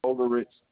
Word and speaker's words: Oellerich: 1008 Overridge Avenue. – 1008 Overridge.